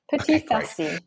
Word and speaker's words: Petits Farcis: So our topic today is the Petits Farcis. Did I pronounce that right? – Petits Farcis.